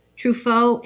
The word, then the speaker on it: Truffaut: Because she has a book, Truffaut. Is that how you say it? Mm-hmm. Truffaut Hitchcock.